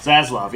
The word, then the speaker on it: Zaslav: It's Zelzov? Zelzov? Oh. David Zelzov. Zaslav, yes. Zaslav, there we go.